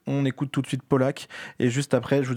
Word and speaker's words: PLK: Bah, justement, on va écouter du hip-hop, un artiste qui s'appelle Polak, ça s'écrit P-L-K, mais ça se lit Polak,